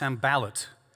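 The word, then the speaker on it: Sanballat: I've been saying, Sambalat's name wrong. It's Sambalat. So I'm going to try and fix that this week.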